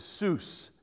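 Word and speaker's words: zuz: he would have to pay me 100 sous. It's a level of currency back then. A Z-U-Z is how you pronounce it.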